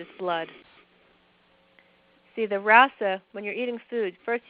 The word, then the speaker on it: rasa: The first tissue is called rasa. It's spelled R-A-S-A.